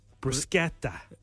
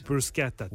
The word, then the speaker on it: bruschetta: No, bruschetta. – Yeah, but that is how you say it. You're saying it right. – Bruschetta.